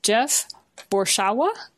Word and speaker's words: Jeff Borschowa: I have with me today Jeff Borschowa.